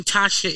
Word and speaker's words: Tatia: And my name Tatia isn't spelled like the traditional Tatia. It's T-A-T-I-A.